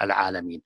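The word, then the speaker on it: Al-Alameen (العالمين): It's written Alameen without Alif in all the Quran, but pronounced everywhere by Urida Al-Alameen.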